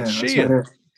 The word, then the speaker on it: Sheehan: And I say this on a, just about every show that I happen to bring it up because I said his name wrong, and he let me know it. Would you say uh, Sheehan? I said Sheehan, and he said yeah, Sheehan. Sheehan, man. The,